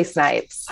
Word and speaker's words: Snipes: Is it pronounced Snipes? – It sure is. Just like Wesley Snipes.